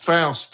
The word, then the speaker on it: Faust: Faust. – Faust.